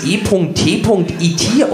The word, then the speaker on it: E.T.: Irgendwann wurde ich aufgeklärt, dass E.T.IT ausgesprochen wird und es sich dabei um die englische Abkürzung für extraterrestrisch handelte, wobei das extra hier nicht etwa für zusätzlich oder umsonst stand, sondern vielmehr für außer.